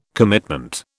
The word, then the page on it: commitment: British English